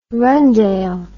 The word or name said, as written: rundale